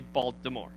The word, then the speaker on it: Baltimore: We don't say Baltimore, we say Baltimore, okay? With a D, right? It's Baltimore. We don't have time to pronounce the T.